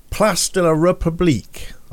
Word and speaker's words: Place de la République: Place de la République. I think I said that right. – That's perfect. I thought, it's perfect French there.